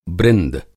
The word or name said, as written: बृंद